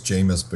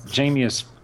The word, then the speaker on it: Jameis: I think it's Jameis, but go ahead. That's good. – Jameis rhymes with famous.